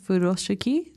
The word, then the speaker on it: Furoshiki: Is it pronounced Furoshiki? – Yeah.